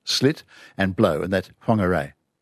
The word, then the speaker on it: Whangarei: And you find that sound actually in Maori in New Zealand and whangarei. It's a wh. – Yeah, even though it looks like an F, but it's pronounced F.